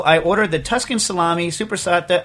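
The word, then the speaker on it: soppressata: But how do you pronounce it, Stas? How's the actual Italian thing? – Supersata. – Supersata. Oh, supersata.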